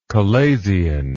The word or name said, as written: chalazion